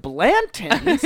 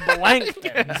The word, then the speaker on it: Blanton's: You don't even know how to say the word Blanton's. There's not a K in there. You said Blankton's.